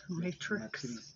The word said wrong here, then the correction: matriz: Matriz. – Huh. Wow, that, isn't that weird? – Matriz, yeah.